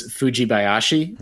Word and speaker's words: Fujibayashi: It was in my Japanese pronunciation, please, I apologize, but I think it's Fujibayashi.